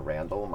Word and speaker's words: Randle: I'm Vakaya Randle. My name is spelled like candle, RANDLE, across all social media platforms.